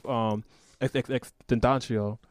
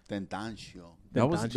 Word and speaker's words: XXXTentacion: He actually has a song with XXXTentacion. – Oh, yeah. – Which is crazy. Rest in peace. So what? How you say his name? – XXXTentacion. – Tentacion.